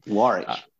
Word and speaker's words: Warwick: Warwick. I want to say it correctly. – Warwick.